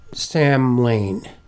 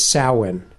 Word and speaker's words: Samhain: So there's this festival called, now I would pronounce this word Samhain. That's the way it looks. However, the real pronunciation I discovered is Samhain. – S-O-W-E-N.